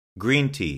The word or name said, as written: green tea